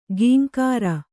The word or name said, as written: ಕಿಂಕರ